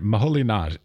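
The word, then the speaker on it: Moholy-Nagy: Well, Budapest in Hungary I can do, but but I've always wondered, Moholy Nagy, I say Laszlo Moholy Nagy. Am I correct? Is that the right pronunciation? – Yes. Yes.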